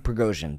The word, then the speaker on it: Prigozhin: Prisgov. – No. It's... I wanted to say Pushkin, but it's not Pushkin. It's Prigozhin? – Prigozhin, thank you.